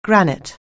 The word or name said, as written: granite